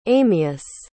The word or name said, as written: amias